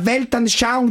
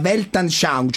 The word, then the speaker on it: Weltanschauung: Ma secondo lei professore, la Weltanschauung di Berlusconi oggi è incentrata sulla gnocca? Cioè la Weltanschauung, cioè la visione del mondo, è una concezione un po' tolemaica quella di Berlusca, per cui gira tutto intorno.